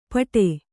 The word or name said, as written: ಪಾಠೆ